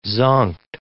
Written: zonked

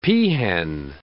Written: peahen

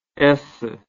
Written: esse

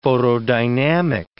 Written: photodynamic

